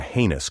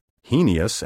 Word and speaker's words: heinous: By the way, the word heinous, spelled H-E-I-N-O-U-S, means reprehensible, wicked, evil, as a heinous crime, a heinous lie. I have heard scores of educated people mispronounce this word as heinous, heinous, and heinous. The best I can say about these pronunciations is that they are creative but wrong. The only pronunciation recognized by dictionaries is heinous, and anything else is utterly heinous, evil, reprehensible.